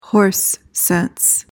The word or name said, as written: horse sense